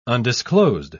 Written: undisclosed

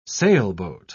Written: sailboat

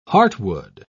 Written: heartwood

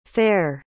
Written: fer

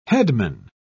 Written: headman

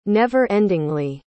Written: never-endingly

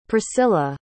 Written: priscilla

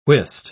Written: whist

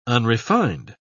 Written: unrefined

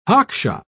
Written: hockshop

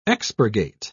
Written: expurgate

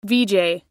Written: veejay

